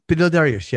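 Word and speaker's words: Beneil Dariush: And am I pronouncing his name different every time? – Benil Dariush, yeah.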